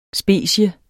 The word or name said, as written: specie